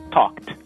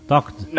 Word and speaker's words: talked: Então, por exemplo, se você quer falar o passado de talk, como o talk no presente termina com um som desvozeado, que é o k, o ED tem uma pronúncia de T, então você fala talked. – Com D, D de dado, talked. – Não, T de tatu.